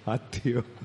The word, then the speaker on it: আত্মা: আত্মা, বা বাংলাতে বলে আত্মা, তয়ময় শব্দটা বাংলায় ত রূপে উচ্চারণ হয়, সংস্কৃততে তয়ময় রূপে উচ্চারণ হয়। যেমন আ তয়ময় দীর্ঘই অন্তুস্থ, কি বলি আমরা? আত্মীয়। কিন্তু উচ্চারণটা হওয়া উচিত আত্মীয়, তেমনি আত্মা।